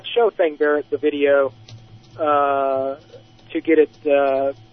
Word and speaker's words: Thang Barrett: Thang Barrett. – How do you spell it? – B-A-R-R-E-T-T. And the first name is Thang, T-H-A-N-G. – Thang Barrett.